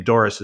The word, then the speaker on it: Dorris: And my last name, Dorris, is spelled D-O-R-R-I-S, two R's.